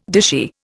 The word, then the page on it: dishy: Indian